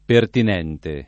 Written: pertinente

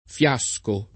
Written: fiasco

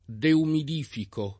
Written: deumidificare

